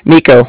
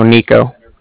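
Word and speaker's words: Niko: Nico. – Can you spell that for me? – Yeah, that's like Nancy I K O. So Nico.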